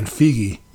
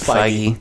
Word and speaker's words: Feige: Yeah, it's I've always been saying Feige. – No, it's Feige.